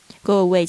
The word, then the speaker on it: go away: 那特别注意一下 “go away” 的这一个发音哦，不是 “go away”，是 “go away” 啊。因为在这个母呃元音跟元音之间呢，如果要发音的话呢，其实会有一个这样的潜规则，特别是两个字之间的这个中间好像夹了一个“ 我” 的声音，就是我们那个音标里面 “w” 那个发音，“ 我我我 ”，“go away” 啊，“go away”，就像那个 Nike 不是有一个非常有名的这个名言叫 “just do it”。